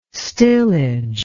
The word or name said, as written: stillage